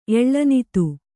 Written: ಎಳ್ಳನಿತು

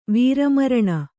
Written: ವೀರಮರಣ

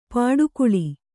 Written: ಪಡುಕುಳಿ

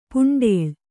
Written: ಪುಂಡೇಳ್

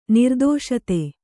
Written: ನಿರ್ಧಾಸ್ತ

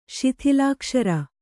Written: ಶಿಥಿಲಾಕ್ಷರ

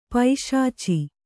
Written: ಪಿಶಾಚ

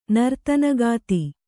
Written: ನರ್ತನಗಾತಿ

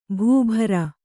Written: ಭೂಭಾರ

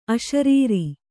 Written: ಅಶರೀರಿ

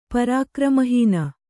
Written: ಪರಾಕ್ರಮಹೀನ

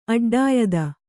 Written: ಅಡ್ಡಾಯುಧ

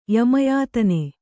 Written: ಯಮಯಾತನೆ

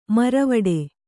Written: ಮುರವಿಡು

